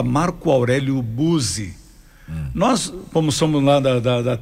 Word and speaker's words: Buzzi: lá a gente chama de Marco Aurélio Buzzi, o 2E com a pronúncia de S.